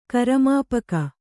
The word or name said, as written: ಕರಮಾಪಕ